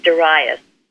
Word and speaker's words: Darius: How do you say Darius? It's Darius.